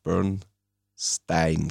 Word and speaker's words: Bernstein: Juni die Uraufführung der Oper A Quiet Place von Leonard Bernstein. Ich glaube, so spricht man aus. Also nicht Bernstein.